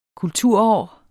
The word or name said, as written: kulturår